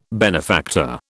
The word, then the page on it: benefactor: British English